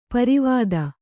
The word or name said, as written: ಪಾರಾವತ